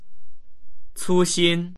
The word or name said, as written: cu1xin1